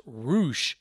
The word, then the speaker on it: Rush: Now, it is spelled Rush, R-U-S-H, but if you see the name Rush as far as a competitor, it is pronounced Roosh, and he took on Bandido.